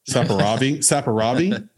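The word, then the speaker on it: Saperavi: This is a how do you pronounce that? Saparavi. It's from Georgia, not the state.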